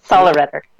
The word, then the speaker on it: Sollereder: Sollereder. Yeah. – Did I say that right? – You got it.